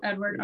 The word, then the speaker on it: Edvard: The other thing I was going to say is I found it's actually Edvard. And Edward. – It is pronounced Edward.